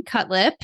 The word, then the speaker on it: Cutlip: Um, so Okay, my name's Morgan Cutlip. Uh, that's a married name, and it's injury to the lip is how you pronounce it.